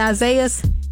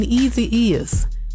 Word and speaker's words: Isaias: She couldn't pronounce, if I pronounce it right, Isaias, Hurricane Isaias. And she said, Hurricane Easy Is.